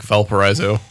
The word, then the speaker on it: Valparaiso: Valparaiso. I think I may have changed the pronunciation there. – I think us white people just call it like Valparaiso.